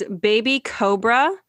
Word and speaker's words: BabyQobra: Now it is Baby Cobra.